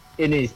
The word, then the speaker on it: Anes: So for people that don't know about me, my name is Anes, H-E-A-G-D-L. Yeah. – Just to double check the pronunciation of igama lakho mfowethu, is it Anes or is it Anes? – Anes, yeah. – So Anes, ne? – Yeah.